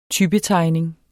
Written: typetegning

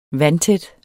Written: vandtæt

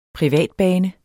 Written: privatbane

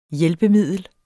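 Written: HJÆLPEMIDLET

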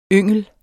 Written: yngel

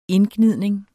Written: indgnidning

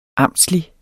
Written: amtslig

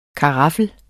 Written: karaffel

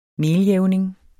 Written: meljævning

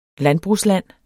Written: landbrugsland

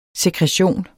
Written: sekretion

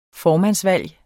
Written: formandsvalg

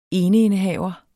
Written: eneindehaver

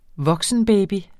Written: voksenbaby

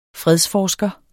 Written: fredsforsker

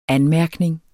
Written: anmærkning

